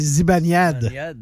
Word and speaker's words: Zibanejad: Elias Pettersson, Rickard Raquel, Lukas Raymond, Alexander Wennberg, Mika Zibanejad. – Oh, celui-là. – Zibanejad, c'est Zibanejad. – Ouais. Il a pas dû se reconnaître souvent dans les descriptions. – Non, hein? – Zibanejad.